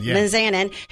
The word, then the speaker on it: Mizanin: Happy birthday to Mike the Miz Mizanin. Did I say it right? – Mizanin, who is 43, WWE superstar, who got his start on the Real World and Road Rules Challenge.